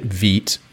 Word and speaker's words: Vite: And I'm like, hey, if I'm running this thing in Vue 3, at this point, I might as well get Vite, which is the way I've decided to pronounce that because I've heard it pronounced that way the most, V-I-T-E.